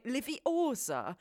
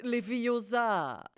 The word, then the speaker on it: Leviosa: Die Betonung liegt bei Leviosa und nicht Leviosa. Das Ding ist, Ron hat es gar nicht falsch gesagt. Zumindest wenn man sich den Film auf Englisch anhört, ist seine Betonung normal. Nur auf Deutsch ist das A bei Leviosa besonders langgezogen.